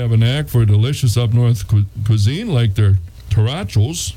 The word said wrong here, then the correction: tachos: Am I saying that right? – Tachos.